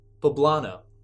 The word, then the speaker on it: poblano: We'll move up to their palomino. – Spell it. – P-O-L-A-N-O. They're green. – P-O-L-O. – Poblano.